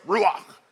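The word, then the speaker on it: Ruach (רוח): You got to say it like you got some popcorn stuck in the back of your throat. Ruach.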